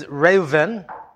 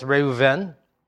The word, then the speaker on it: Reuven: Reuben is Reuven, and so the last part actually has the emphasis, Reuven.